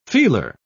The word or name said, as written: feeler